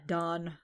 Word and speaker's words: Dan: And then he also sees Asuma and Don, who's not named Dan, even though it's spelled Dan.